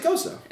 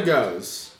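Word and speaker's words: gose: Is that how you pronounce it? Yeah. You just said that, so I was repeating what you said. – No, that's how you said it. It's not a goes.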